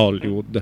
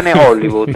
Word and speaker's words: Hollywood: Voglio apparare grosso, guarda, Hollywood. – Hollywood, no, hai detto bene Hollywood,